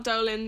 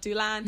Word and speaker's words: Doolan: It's just Jessica Doolan. A lot of people get my second name wrong. It's D-O-O-L-A-N. Not Dolan, not Doolan.